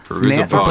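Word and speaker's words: Fairuza Balk: Feruza Balk. – Feruza Balk. – Played Nancy Downs from The Craft. Is that how you say her name? – I forget. Feruza Balk. – Yep.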